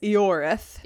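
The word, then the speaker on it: Ioreth: Pronouncing, I have been pronouncing as Iorath. – Okay, but in the video game, it's Eorath. – Yeah, I think that's more of like an old English type of pronunciation.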